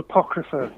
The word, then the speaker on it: Apocrypha: A lot of what's on the album, the album's called Apocrypha, by the way.